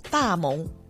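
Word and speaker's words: 大濛: 大蒙在国语读大蒙。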